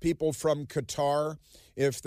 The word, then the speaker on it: Qatar: And here's how to pronounce Qatar. It's pronounced Qatar.